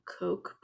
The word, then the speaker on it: Koch: A lot of it is like the Murdochs and like the, I'm going to pronounce their name wrong, the Koch brothers. – Yeah, that's okay. Correct.